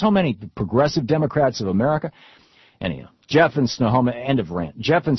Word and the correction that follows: Snohomish: I think I just mispronounced your town, though. – Snohomish, that's how it is. – Snohomish, there you go.